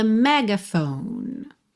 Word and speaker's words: megaphone: Megaphone, não pronuncia o F final, tá bem? A sílaba tônica é a primeira, mas a gente acaba tendo assim uma semitônica, né? Um outro ponto de apoio aí no meio. Megaphone, megaphone.